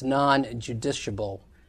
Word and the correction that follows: justiciable: if I pronounce that word correctly. – Justiciable. – Thank you.